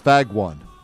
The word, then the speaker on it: Fagone: And her last name is spelled F-A-G-O-N-E. – Okay. – It sounds like it's pronounced Fagone, but it's pronounced Fagwan. – Fagwan? – Yeah, it doesn't matter. It's spelled F-A-G-O-N-E.